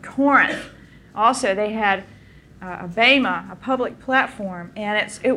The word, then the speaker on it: bema: It's a Greek word, bema, B-E-M-A, long E.